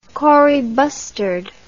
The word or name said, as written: kori bustard